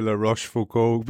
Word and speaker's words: La Rochefoucauld: And I'm so glad you pronounced Monsieur La Rochefoucauld, because I had it in my notes and I was like, going, I hope he says it first.